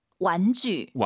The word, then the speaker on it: 玩具: 장난감을 중국어로 완쥐. – 완쥐라고 합니다. 제이 유발음 잘하세요? 주 아니고 쥐입니다, 여러분. 완쥐.